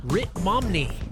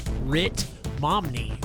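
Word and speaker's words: Ritt Momney: Coming up next, we have Rit Momney with Dog. Yes, I said that right. Rit Momney with Dog.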